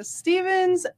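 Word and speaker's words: Stephens: My name is Mona Stevens, and I am the development director over at Yavapai Casa for Kids Foundation. – Stevens is spelled like that. Stevens. – With a PH.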